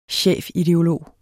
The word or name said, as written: chefideolog